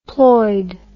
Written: ploid

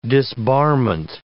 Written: disbarment

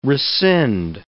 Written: rescind

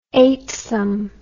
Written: eightsome

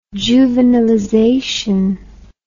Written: juvenilization

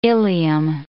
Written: ileum

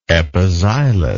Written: epixylous

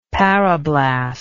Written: parablast

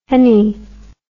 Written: henny

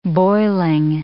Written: boiling

